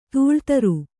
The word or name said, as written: ದಿಲ್ದಾರ